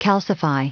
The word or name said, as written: calcify